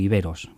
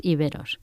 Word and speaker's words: íberos: Bueno, quizás lo primero que tendríamos que hablar es una cuestión de pronunciación, íberos o iberos, como se suele decir. Lo podemos escuchar de las dos maneras, ¿cuál sería la correcta? – Pues hay una manera correcta, que es íberos.